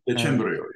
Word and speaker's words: Decembrio: And there's another figure you talk about in the book, Angelo Decembrio, if I'm pronouncing the last name correctly. I hope I'm pronouncing it correctly. – Yeah, Decembrio. Angelo Decembrio.